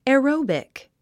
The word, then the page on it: aerobic: εəróubik; エロォビック